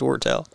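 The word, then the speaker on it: Wirtel: I've always called him Steve Wordle. – Wortel. I'm pretty sure it's Wortel.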